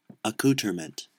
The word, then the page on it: accoutrement: American English